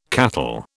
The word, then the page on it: cattle: British English